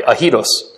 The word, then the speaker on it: aidos: The one we're talking about here when we talk about God is pronounced aidos. I'm sorry, ahidos.